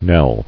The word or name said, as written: Knelled